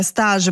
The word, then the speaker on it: stage: Allora, stage pronunciato all'inglese, quindi stage, significa palcoscenico oppure anche tappa, no? Di un processo o fase di un progetto. Per esempio si usa lo stage di danza, no?